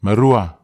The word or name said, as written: marua